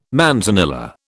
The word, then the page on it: manzanilla: British English